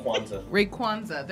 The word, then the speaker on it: Rayquaza: Am I saying that right? – Rayquaza. – There's no N in here.